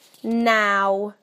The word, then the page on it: now: British English